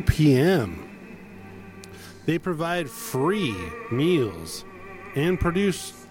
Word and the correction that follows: produce: Sorry, I pronounced that wrong. I put the emphasis on the wrong syllable. They provide free meals and produce to anyone who is hungry.